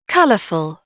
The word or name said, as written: colorful